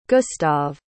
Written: gustave

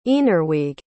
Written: eanruig

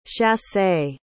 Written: chassé

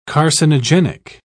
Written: carcinogenic

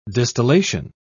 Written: distillation

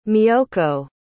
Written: miyoko

